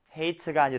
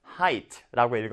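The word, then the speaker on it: height: 그 다음, 어떻게 읽어야 한다? height가 아니라 height라고 읽어야 한다.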